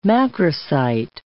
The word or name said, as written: macrocyte